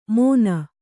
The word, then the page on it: ಮೋನ: Kannada